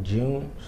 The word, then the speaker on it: Jeune: It's Patrick June. So that's patrick.june, which is spelled J-E-U-N-E.